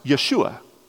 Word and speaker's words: Yeshua: In Hebrew or Aramaic, it's pronounced Yeshua.